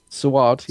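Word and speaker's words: Saward: Eric Sayward, am I saying that right? – Sayward, yeah.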